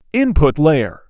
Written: Input layer

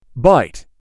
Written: Byte

